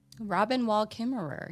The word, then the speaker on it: Robin Wall Kimmerer: Robin Wall Kimmerer, yeah.